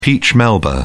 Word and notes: peach melba: British English

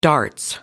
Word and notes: darts: British English

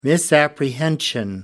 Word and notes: misapprehension: American English

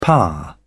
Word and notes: pa: British English

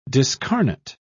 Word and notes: discarnate: British English

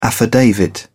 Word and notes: affidavit: British English

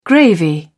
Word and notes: gravy: British English

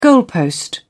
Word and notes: goalpost: British English

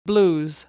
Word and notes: blues: American English

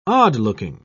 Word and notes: odd-looking: British English

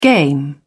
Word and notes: game: British English

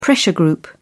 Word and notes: pressure group: British English

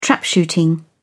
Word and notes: trapshooting: British English

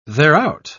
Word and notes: thereout: British English

